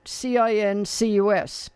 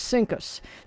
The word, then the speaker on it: CINCUS: The top U.S. Navy command was CINCUS. And what it was Commander-in-Chief U.S. Fleet, CINCUS. But when they pronounced it, it was CINCUS. So, yes, the top Navy command had the had the title of CINCUS.